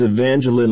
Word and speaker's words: Evangeline: It's Evangeline, like Jacqueline.